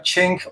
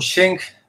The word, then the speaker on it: Sheng: Este instrumento que están viendo eh, ustedes se llama Cheng o Sheng, según eh, se pronuncia ya sea en español o en inglés.